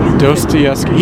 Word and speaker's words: Dostoevsky: Dostoevsky. – Dostoevsky.